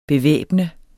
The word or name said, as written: bevæbne